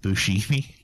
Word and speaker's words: Buscemi: It's pronounced Buscemi.